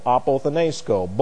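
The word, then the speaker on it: apothenesko (ἀποθνῄσκω): And the accent goes here, and then we have S-K-O, long O on the end. This is A-P-O-T-H-N-E-S-K-O, apothenesko.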